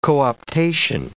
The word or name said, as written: co-optation